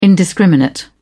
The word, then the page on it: indiscriminate: British English